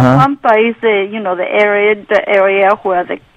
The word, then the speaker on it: La Pampa: But it's La Pampa. It's an A. It's Spanish is pronounced A. Pampa.